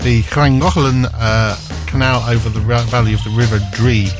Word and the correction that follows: Dee: or Dee, even shall I say, in Wrexham in North East Wales.